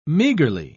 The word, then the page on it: meagrely: British English